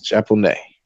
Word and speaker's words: Japonais: But Japonais is spelled Japanois. Japonais.